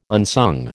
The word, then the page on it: unsung: American English